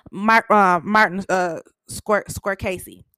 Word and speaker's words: Martin Scorsese: Martin Scorsese.